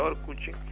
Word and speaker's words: Orkut: Orkuchi se escribe con T y en Brasil Orkuchi. Se pronuncia Orkuchi.